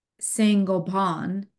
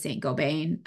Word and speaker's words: Saint-Gobain: And then the gentleman from they pronounce it Saint-Gobain, because it's French. And I was like, oh, so we know it as Saint-Gobain. But I love the way he said it.